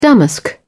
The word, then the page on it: damask: British English